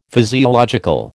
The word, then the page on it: physiological: American English